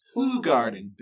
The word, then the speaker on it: Hoegaarden: Hoegaarden. – Hoegaarden. There's no W in there. – Yeah, but it's not from this country. It's not American. – So it's pronounced Hoegaarden. – Yes, sir.